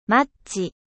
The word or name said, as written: allumette